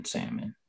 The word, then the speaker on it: salmon: Well, that, that that was spoken by someone who sounds like they pronounce the L in salmon.